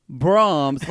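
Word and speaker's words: Braum's: But it's spelled Brahms like the ice cream store. B-R-A-U-M apostrophe S.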